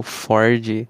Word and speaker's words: Ford: Mas é claro, é o glorioso Ford, que a gente pode até tirar. O, o, R, o R é mudo, não tem, não tem som R. Claro, o Ford Pinto.